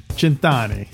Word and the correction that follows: cento: Oh, close. Cento.